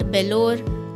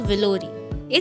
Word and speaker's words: Vellore: वेलोर not बेलोर not वेलोरी it's वेलोर